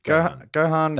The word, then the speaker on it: Gohan: Gohan, am I saying that right? – Yeah. – Yeah. – Gohan.